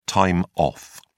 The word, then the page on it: time off: British English